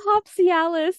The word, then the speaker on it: Hopsialis: Also, Boat Shoes Hazy IPA and Aurora Hoppy Tails IPA. And then for the food, because did I say that right? Hoppy. – Aurora Hopsialis, like Aurora Borealis.